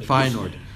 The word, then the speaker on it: Feyenoord: Sorry. – That's my Dutch pronunciation. – Feyenoord. And three goals versus Watford.